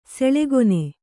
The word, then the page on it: ಸುಲಗ್ನ: sulagna